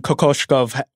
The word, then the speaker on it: Kokoshkov: And there was a lot of that when Kokoshkov was coaching.